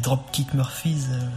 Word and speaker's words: Dropkick Murphys: Ça me fait penser au groupe Dropkick Murphys. Enfin, je sais pas si je le prononce bien, mais c'est. – Voilà, c'est exactement ça. C'est ça.